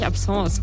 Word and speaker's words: Absence: Also er hat wohl temporär kurz auftretende Abszenen, die auf eine neurologische Dysfunktion zurückzuführen sind. Ich hoffe, ich habe es richtig ausgesprochen. – Wahrscheinlich Absence, wenn er so weg ist. – Absence, ja, wahrscheinlich Absence. Ja.